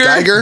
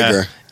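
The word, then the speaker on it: Guyger: Geiger. – Geiger. – Geiger. – Yeah. Yeah. Geiger.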